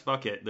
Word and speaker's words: Phuket: In buying flights back home, a one-way ticket from, I assume that this is pronounced fuck it. – Just so everyone's clear, it's phuket. Phonetically, that's fuck it. There's no other way to pronounce that.